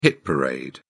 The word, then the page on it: hit parade: British English